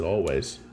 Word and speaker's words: Alvvays: We have Alvvays or Alvvays. – With two V's. I think they just pronounce it Alvvays.